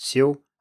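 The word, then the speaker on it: xiu (休): and combine them into new character, which is pronounced xiu and means to rest, so you get the idea of someone resting in the shade of a tree.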